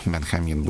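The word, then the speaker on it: Benjamín: Зовут его Бенджамин или Бенхамин. Бенхамин будем по-испански произносить.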